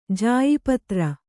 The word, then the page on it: ಜಾಯಿಪತ್ರ: jāyi patra